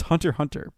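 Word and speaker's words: Hunter × Hunter: It's Hunter Hunter, but how else do you spell it? – You got to add the X's in it.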